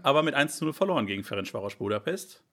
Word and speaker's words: Ferencváros: Ferencváros, du kannst es besser aussprechen. – Ferencváros, ja.